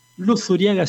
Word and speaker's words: Luzuriaga: Luzuriaga, así como lo pronuncio, con Z, Club Social.